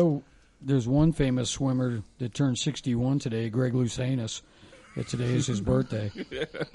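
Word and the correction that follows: Louganis: That's Luganus. That's Luganus.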